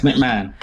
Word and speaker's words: McMahon: Vince McMahon.